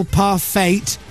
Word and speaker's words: parfait: Granny Smith apple parfait and donuts. Bingo. That's all you need. – Parfait. You don't pronounce the T.